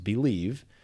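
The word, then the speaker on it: Bleav: We are on the Believe, it's B-L-E-A-V, it's pronounced Believe, .com podcast network.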